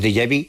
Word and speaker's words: déjà vu: lo que llamamos déjà vu, que eso, mira que bien lo pronunciado. – Sí, muy bien, muy bien. Déjà vu, sí.